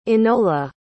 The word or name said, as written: enola